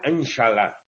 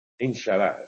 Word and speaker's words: Inshallah: Ojalá es una palabra árabe que tiene totalmente la reminiscencia antigua, porque en árabe, yo no sé pronunciarlo bien, ¿no? Pero es algo así como Inshallah. – Inshallah, efectivamente, ¿eh?